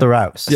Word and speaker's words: Theroux: That's definitely how you say his name. Yeah. – Theroux. – Yeah.